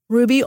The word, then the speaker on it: Ruby: Ruby, R-U-B-Y, Guillen, G-U-I-L-L-E-N.